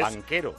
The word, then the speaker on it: Banchero: Bueno, en Duke se ha ido ahora un jugador excepcional que se llama Paolo Banchero, que os sonará italiano y es porque es... – Banquero. – Banchero, se escribe.